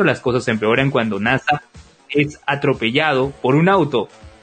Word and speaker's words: Nasa: La historia sigue a un protagonista cuyo nombre está escrito con los caracteres de Hoshisora, cielo estrellado en japonés, pero cuyo nombre se pronuncia como NASA.